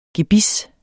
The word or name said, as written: gebis